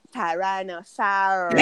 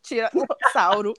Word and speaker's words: Tyrannosaurus: É outra pronúncia. Tyrannosaurus. É. Aí eu, eu tiranossauro, Tiranossauro.